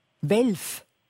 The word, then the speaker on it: Welf: Welf? – Jawohl. – Wie schreibt man das? Also W-E-L-F.